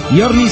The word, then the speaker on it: giorni: Giorni se pronuncia giorni, me dice Ande, me dice Fergus.